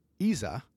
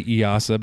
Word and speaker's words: EASA: Yeah, the 11-seat Technam P2012 is called the Traveler, and it's been certified by the European Aviation Safety Agency, or ESA. Right? Is that how you pronounce it? – I always say EASA, but you know, yeah.